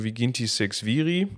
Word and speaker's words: Viginti Sex Viri: Und das Ganze fing an, beziehungsweise fängt an mit dem, mit den, das hast du eben schon mal angesprochen, Viginti Sex Viri. Spricht man das so richtig aus? Genau, das sind die, also Viginti sind die 20, 6, schreibt sich ziemlich lustig, haha.